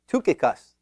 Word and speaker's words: Tychicus: Is it a long I, Tychicus, or is it a short I, Tychicus? Actually, strange thing, in the Greek, it is a U sound, an upsilon, so it's really Tychicus.